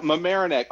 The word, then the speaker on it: Mamaroneck: Coming to us from, am I pronouncing it right? Is it Mamaroneck? Is that where you're at? – Yeah, Mamaroneck, although I am actually back in the hotel room at White Plains because, look, the U.S.